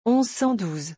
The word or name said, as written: 1112